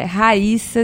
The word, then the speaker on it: Rhaissa: E enquanto isso, eu vou explicando para todo mundo que eu, com quem eu cruzo o caminho, que meu nome é Raíssa, se escreve com R-H-A-I-S-S-A. Bitar é com dois T's, é B de bola.